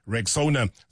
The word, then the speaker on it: Rexona: A brand that you've trusted for years and years and years is stepping forward with their bold new look, and it's pronounced Rexona.